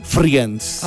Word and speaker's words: Friends: En el año 1956 nace Marta Kaufman, productora estadounidense, creadora de la serie Friends.